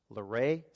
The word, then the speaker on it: Luray: It's called Luray. Is that how you pronounce it, Thomas? All right, I got it right for once.